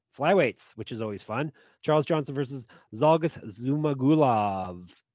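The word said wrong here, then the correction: Zhalgas Zhumagulov: Do you know you're saying it wrong? Would that help if I told you that? – Really? I'm saying Zacko wrong? – It's Shalgas Zumagulov.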